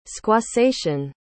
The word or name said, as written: squassation